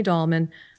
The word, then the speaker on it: Dahlman: Dahlman.